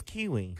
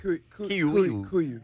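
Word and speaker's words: Kuiu: I thought it was Kiwi. – Kuyu.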